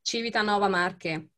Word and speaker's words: Civitanova Marche: Civitanova Marche.